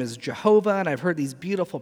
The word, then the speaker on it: Jehovah: You see, again, the squiggly lines I said below, the vowels, they pulled the vowels under from Adonai, put them in on the divine name, and then it comes out with the pronunciation, at least in the Latin at the time, Jehovah. How many of you guys have ever been taught that God's name is Jehovah?